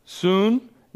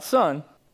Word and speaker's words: sun: Soon, not sun. It's pronounced soon in the Greek. S-U-N, soon. Anti, A-N-T-I, and then lambano.